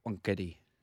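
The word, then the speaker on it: Wankhede: How do you say it? – It's wankheady. Yeah.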